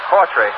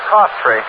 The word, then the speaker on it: Coughtry: That name is spelled C-O-U-G-H-T-R-Y and pronounced Cortry. I haven't corrected. It's Cortry.